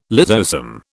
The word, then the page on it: lysosome: British English